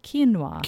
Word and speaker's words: quinoa: They might say quinoa, but it is pronounced quinoa. – Quinoa. – It's spelled Q-U-I-N-O-A.